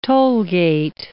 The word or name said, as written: tollgate